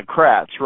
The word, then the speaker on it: Kratz: Is that, did I say his name right? – Yeah, Kratz, right.